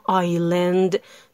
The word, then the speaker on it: island: کلمه island بود که I-S-L-A-N-D نوشته میشد، اما آیلند تلفظ میشد.